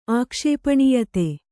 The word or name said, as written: ಆಕ್ಷೇಪಣೀಯತೆ